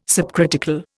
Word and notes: subcritical: Indian

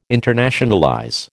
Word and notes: internationalize: American English

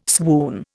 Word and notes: swoon: Indian

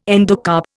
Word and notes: endocarp: Indian

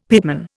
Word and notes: pitman: Indian

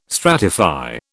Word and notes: stratify: British English

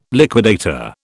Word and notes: liquidator: British English

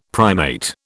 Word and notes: primate: British English

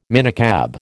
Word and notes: minicab: American English

